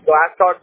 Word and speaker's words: Glasscock: It's going to be Glasscock, G-L-A-S, and then C-O-C-K, so like glass, but with one S and then C-O-C-K.